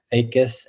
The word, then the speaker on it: Facas: Uh, feel free to to find me on LinkedIn, uh, E R I C Eric Facas F A C A S.